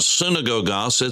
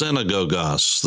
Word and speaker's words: synagogos: Synagogos is the Greek pronunciation, but in English we pronounce it not soon. We change the U to a Y rhythmically uh, when you transliterate. And so it's not sunagogos, it's synagogos, the synagogue.